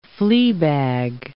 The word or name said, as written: fleabag